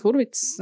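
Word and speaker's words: Hurwitz: uh, tā ir, kā jau minēju, Dēmijas Čezela filma, un mūziku tā ir sarakstījis uh, Justins uh, Hervits, Hurvits. Uh, nezinu, kā mēs viņu latviešu tā smuki izrunāsim, bet uh, viņš arī sarakstīja uh, citām Čezela filmām mūziku, tostarp arī Lālēnu, par ko viņš dabūja Oskaru.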